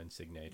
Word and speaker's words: Insigne: I know Insigne just, yeah. – I don't know if that's even saying his name properly. – You got it, yeah. Nailed it.